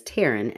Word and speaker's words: Taryn: Okay, but it's fitting because my name is Taryn and it's pronounced like Aaron.